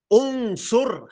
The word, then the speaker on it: انصر: انصر همزة الوصل تنطق مضمومة انصر أخاك ظالما أو مظلوما انصر أخاك ظالما أو إيه أو مظلوما بضم حمزة الوصل لأن العين مضمومة ونكسرها إن كان مكسورا يعني إن كانت الخي...